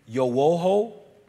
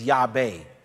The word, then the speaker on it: Yahweh: In the 4th century, a church father, Theodoret of Cyprus, confirmed in Egyptian writings that the Hebrew pronounced it as Yahweh.